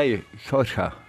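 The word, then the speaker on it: Jorja: Wie schreibt man denn das? – Mit zwei J. J-O-R-J-A. – Ja, Jorge.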